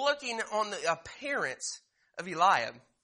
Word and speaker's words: Eliab: It says in verse 6, "And it came to pass when they were come and looked on Eliab," or Eliab, however you would want to pronounce it, pronunciations both way, Eliab, "and said, surely the Lord anointed is before him.